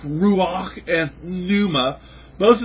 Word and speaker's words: ruach: You've got to say it like that, ruach, with a little phlegm in the back of your throat.